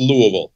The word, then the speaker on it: Louisville: Yeah, which everybody pronounces Louisville, Louisville. It's just Louisville. It's like you got punched in the mouth and tried to say Louisville.